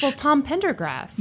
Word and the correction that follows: Pendergast: Yeah. – I totally mispronounced that last Pendergast. – Name. – Pendergast.